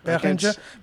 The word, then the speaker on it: Behringer: It's a Behringer. – Sounds good to me. – Behringer.